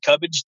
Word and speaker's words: Cubbedge: It's David Cubbage, D-A-V-I-D. Last name is spelled C-U-B-B-E-D-G-E. It's Cub with two B's and Edge.